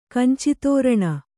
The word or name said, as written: ಕಂಚಿತೋರಣ